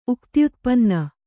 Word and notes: ಉಕ್ತ್ಯುತ್ಪನ್ನ: uktyutpanna

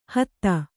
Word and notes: ಹದ: hada; Kannada

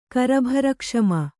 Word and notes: ಕರಭರಕ್ಷಮ: Kannada

